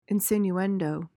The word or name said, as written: insinuendo